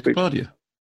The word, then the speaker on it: Kapadia: And I think it was Asif Kapadia. I can't pronounce his surname now. – Asif Kapadia. – I said, Kapadi, that's what I said. I added an A for no reason.